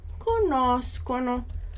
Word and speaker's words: conoscono: Então repara que essa pronúncia mais dura, co, a gente tem só no conosco e no conoscono.